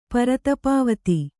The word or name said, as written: ಪರತಪಾವತಿ